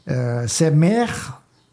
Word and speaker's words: Médard: Et oui, on dit Saint-Méard, et on dit alors Saint-Méard, 40 jours maïter.